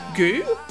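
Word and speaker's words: Goux: We've got John Goo. His name's G-O-U-X. I'm going to say that's pronounced Goo.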